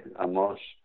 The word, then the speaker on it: Amos: No, I say it in the Israeli way. Amos.